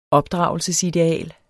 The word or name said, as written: opdragelsesideal